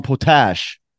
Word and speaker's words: Potash: And AT&T Sportsnet's Pittsburgh's Dan Potash. Did I say his name right? – Yes.